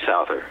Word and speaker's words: Souther: So it's Souther. – It's definitely Souther. – It sounds just like it looks.